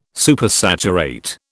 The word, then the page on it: Supersaturate: British English